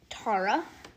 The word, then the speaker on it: Tara: The best is Tara. It is Tara, not Tara. It's Tara. I was mispronouncing. It's Tara because of Tara cards. That's why it's Tara.